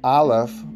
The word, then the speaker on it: Aleph: Uh, another thing to note, Aleph, when you say it, is not really an A. It's a silent letter, so if you were to say it by itself, it's silent.